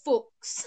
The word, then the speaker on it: Fuchs: F-U-C-H-S. – F-U-C-H-S. – Yeah. – Fox.